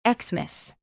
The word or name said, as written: xmas